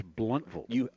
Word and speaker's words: Blountville: And you can't say Blountville. It's Blountville.